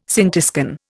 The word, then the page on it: scintiscan: Indian